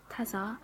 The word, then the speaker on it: Tsar: Tasca, Tazar, Nicholas.